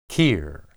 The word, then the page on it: qir: hebrew